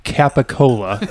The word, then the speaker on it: capicola: I mean, it's phonetically pronounced capicola, and it's turned into gabagool.